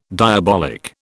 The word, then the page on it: diabolic: British English